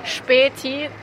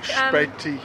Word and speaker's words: Späti: Späti. They're like... – Späti.